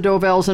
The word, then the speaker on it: Dovells: Del Vos. – Number six. – Del Vos. I'm not saying it right. – That's all right. You know it's the Dovells, and I'll give it to you for that.